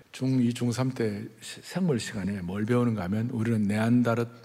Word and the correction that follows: 네안데르탈인: 네, 발음 네안데르탈인 크로만용인 자바원인 다 원숭이들 아니에요, 다들? – 네?